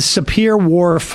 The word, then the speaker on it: Sapir-Whorf: Talk about the Sapir-Whorf hypothesis.